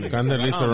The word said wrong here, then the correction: Carnalismo: right? – No Condalismo. – Oh, Carnalismo. – Carnalismo. – Pronounce the R.